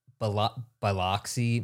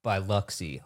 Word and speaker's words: Biloxi: We said the word Biloxi, Mississippi. And someone commented, it's pronounced by Luxie. Lox is something you put on a bagel. And I'm like, that's literally exactly the word that's in the word.